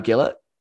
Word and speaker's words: Gillett: So there was a red card that was initially given by Jared Gillett, rhymes with Fillet.